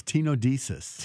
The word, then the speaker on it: tenodesis: I can never pronounce it. What did I have again? – Biceps tenodesis. – Tenodesis.